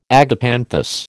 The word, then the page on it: agapanthus: American English